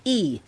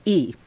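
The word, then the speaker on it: Yi (義): For instance, the character for righteousness is Yi. That's how you say it, Yi.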